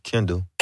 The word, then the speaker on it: KNDL: Yeah, that's Kendall. But he got it abbreviated. – Yeah. He took the vowels out of it.